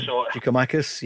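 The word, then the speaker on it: Giakoumakis: Giacomakis, yep.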